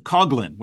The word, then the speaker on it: Coughlin: Well, I've been schooled that we pronounce it Coughlin with a hard Coughlin.